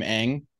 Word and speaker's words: Ng: Kim Ng, I believe is how you pronounce her name.